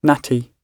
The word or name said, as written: natty